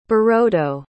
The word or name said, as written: birodo